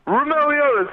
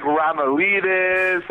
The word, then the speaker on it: Roumeliotis: I've gotten, like, Roumeliotis. I've gotten, like, Romeo Lotus.